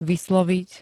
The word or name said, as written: vysloviť